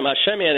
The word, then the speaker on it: Hashemian: You got it right the first time, Hashemian, and you pronounce it like a pro.